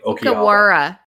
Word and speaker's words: Okawara: In both Japanese and Korean, there's no R per se. It's right between R and L. – How do you say it? – Like Okawara.